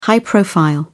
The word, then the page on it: high profile: British English